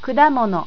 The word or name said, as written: kudamono